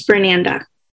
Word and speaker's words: Fernanda: Yeah, my name is Fernanda. – Fernanda. – Like Fernando, but with the A at the end. Fernanda. Like Fernando.